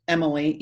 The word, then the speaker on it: Emily: And my name is spelled Emily, E-M-I-L-Y, H-A-R-M-A-N.